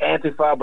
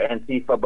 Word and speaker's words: Antifa: I pronounce it Antifa, but you sometimes hear Antifa. But remember, Antifa is short for anti-fascist. – Right. – So Antifa is the proper way of pronouncing it.